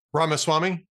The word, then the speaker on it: Ramaswamy: Ramaswamy? – There you go.